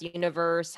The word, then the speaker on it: Univers: I appreciate the integrity of a name of a font, but it is tough when you know Univers is pronounced universe, Helvetica Neue, Helvetica New.